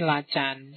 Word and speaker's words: Lacan: Yang pertama Freud, yang kedua Roland Barthes, yang ketiga Lacan. Tulisannya Lacan, tapi mocone Lacan. Ya, bahasa Perancis.